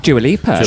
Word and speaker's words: Dua Lipa: That was Don't Start Now by, how would you say that, Dua Lipa? – Dua Lipa.